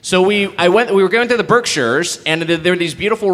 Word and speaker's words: Berkshires: How do you say it? – Berkshires, yes.